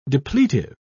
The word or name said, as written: depletive